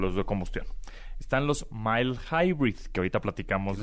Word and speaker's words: mild: Luego están los mild hybrid. Mild se escribe M-I-L-D.